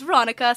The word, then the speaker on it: Veronika: And as you can guess from the title, my name is Veronika, spelled with a K.